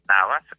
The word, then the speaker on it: Navas: Navas es con B de Valencia y S al final.